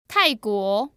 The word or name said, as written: tai4guo2